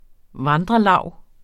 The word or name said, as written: vandrelav